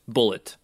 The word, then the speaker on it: Bulleit: Even though it's spelled B-U-L-L-E-I-T, it's still pronounced Bullet.